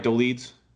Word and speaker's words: Dolidze: Uh, am I getting that right, Dolidze? Yeah. Cool.